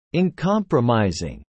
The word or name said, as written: uncompromising